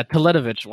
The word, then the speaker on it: Teletovic: Yeah, Teletovic. Wow, good good pronunciation a true basketball addict.